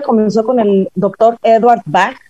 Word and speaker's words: Bach: Bueno, el bueno, que lo estoy pronunciando mal porque yo lo digo como si fuera alemán, es Bach. CH en alemán es como una G.